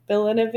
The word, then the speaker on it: Denis Villeneuve: Denis Villeneuve? Villeneuve.